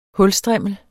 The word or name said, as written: hulstrimmel